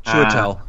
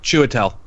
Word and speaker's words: Chiwetel: Chiwetel. – How do we say? – Chiwetel. – Chiwetel, and how's the last name? – Ejiofor.